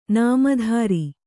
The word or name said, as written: ನಾಮದಾರ